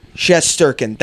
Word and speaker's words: Shesterkin: Shesterkin. – That's what the pronunciation on Google says.